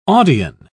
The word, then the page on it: audion: British English